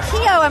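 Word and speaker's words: Keough: This is Riley Kehoe.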